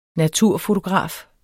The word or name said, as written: naturfotograf